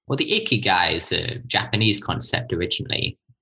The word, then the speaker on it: ikigai: Ikigai, am I pronouncing it right? – Yep, ikigai.